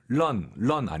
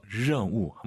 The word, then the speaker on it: 任务: 알발음 런 아니고 더 혀를 뒤로 보내서 任务 한번 더.